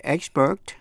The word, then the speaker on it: expert: Vậy expert là danh từ hay tính từ phát âm như nhau, đều nhấn vần đầu, expert. Danh từ expertise, E-X-P-E-R-T-I-S-E, expertise, là nhấn vần 3, có nghĩa là tài năng chuyên môn.